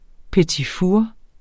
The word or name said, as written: petitfour